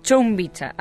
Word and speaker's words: Chumbicha: Así es, tuviste la posibilidad de estar, ahora sí lo voy a pronunciar bien, lo prometo, es Chumbicha,